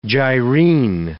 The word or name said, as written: gyrene